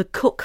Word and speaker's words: Coke: You're from the Cook family, that's how I pronounce it, isn't it? – Absolutely. – Yes. – It's spelt Coke, but you pronounce it Cook.